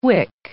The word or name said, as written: wick